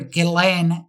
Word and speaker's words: Ghislaine: His daughter, Ghislaine, that's the way you pronounce it.